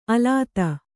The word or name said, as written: ಅಲತೆ